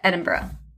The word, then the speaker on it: Edinburgh: Edinburgh? – Is that how you say it? – Yeah, Edinburgh.